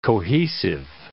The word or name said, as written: cohesive